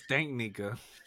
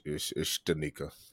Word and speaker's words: Stanika: Well, how do you say her name? – Stanika. – I thought it was Stanknika. – Yeah, like I'm pretty sure she done had so many bad nicknames growing up. Stanknika. Yeah, it's Stanika.